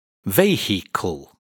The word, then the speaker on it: vehicle: Vehicle. In fact, this word is pronounced as a two-syllable word. The I in the middle is not pronounced.